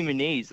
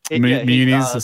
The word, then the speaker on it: Muniz: Muniz, sometimes Muniz. Obviously, Rowan's preferred pronunciation.